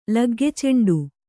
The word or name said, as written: ಲಗ್ಗೆಚೆಂಡು